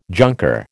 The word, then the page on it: junker: American English